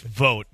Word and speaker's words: Vogt: Now, we are in the midst of Stephen Vogt, is how you say it? – Yes. – He interviewed for the Giants job. – I think it's Vogt.